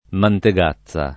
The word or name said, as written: Mantegazza